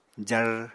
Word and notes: jar: apatani

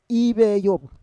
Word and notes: iveyov: Roon

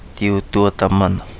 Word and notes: Di Udua Damana: Kapingamarangi

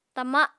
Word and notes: tama: apatani

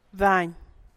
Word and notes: Bac: Ba’ahc

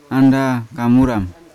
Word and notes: anda kamura: Yawa

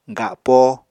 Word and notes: gapo: apatani

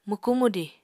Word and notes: mwkú-mwdiñ: Apatani

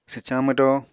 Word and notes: swcañ mwdo: apatani

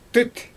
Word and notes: tɯt: Xyzyl

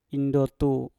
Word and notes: indotu: Mpur

